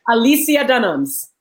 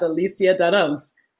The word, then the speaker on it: Alicia Dunams: Alicia Dunams. – Alicia Dunams. – Yes, Alicia Dunams.